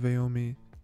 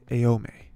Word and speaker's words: Aomine: I think we'll see the full extent of Aomine's. I like to say just Aome, but there's an extra syllable in there. Aomine.